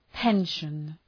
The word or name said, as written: pension